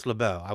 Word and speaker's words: LaBeouf: No, it's LaBeouf. I was just asking if that was a dude who was in the movie. – Is it LaBeouf? – Shia LaBeouf.